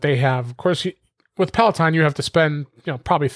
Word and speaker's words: Peloton: He kept mispronouncing it. I didn't want to correct him. It was hilarious. Peloton. But it's Peloton.